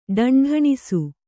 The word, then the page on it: ಢೆಂಢೆಣಿಸು: kannada